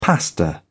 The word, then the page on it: pasta: British English